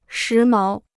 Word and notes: 时髦: shí máo

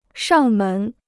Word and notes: 上门: shàng mén

